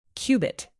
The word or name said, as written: cubit